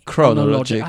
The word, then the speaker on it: Chronologic: So am I saying it wrong when I say chronologic? Do I need to put more emphasis on it? – No, it's very good.